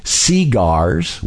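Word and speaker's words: segars: Cigars in the South were spelt S-E-G-A-R and pronounced cigars with the emphasis on the first syllable.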